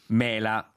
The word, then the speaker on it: mela: Poi, noi chiamavamo però mela con accento sulla E, mela. Non credo che c'erano di mezzo le mele. Quando andrò in paese chiederò qualche mio coscritto. Grazie, buona domenica Leandro. Mela. O mela. – No, l'accento qui conta. – L'accento acuto è il frutto e la mela, accento grave, è ben altra cosa.